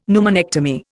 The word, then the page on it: pneumonectomy: Indian